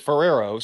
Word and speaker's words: Ferraro: No, it's definitely spelled like Ferraro. My father is Italian and going back for years Italian, and he pronounces it Ferrero. So I just go by what my Italian father wants to pronounce it.